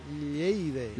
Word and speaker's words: Lleida: lo he dicho bien, Lleida. – Lo has dicho bien por una vez, oh, sí señor.